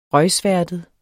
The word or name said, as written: røgsværtet